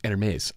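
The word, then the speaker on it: Hermès: And same thing is true with this Hermes envelope.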